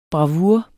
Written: bravur

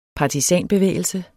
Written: partisan

